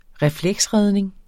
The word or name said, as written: refleksredning